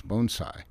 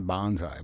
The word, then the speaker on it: bonsai: Well, I'd chuckle and tell them the correct pronunciation was bonsai, not bonsai.